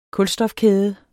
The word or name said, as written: kulstofkæde